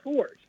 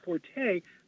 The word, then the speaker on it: forte: You should say fort. – Oh, gee. – But most people think you should say, because it comes from the French and the E is silent at the end in that formation. but because most people say forte, so it gets to the point when you say it, you go, well, that's not my fort or forte.